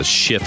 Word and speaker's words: Schiff: What'd you think yesterday if you were able to watch all or part of the, they're calling it the Schiff Show.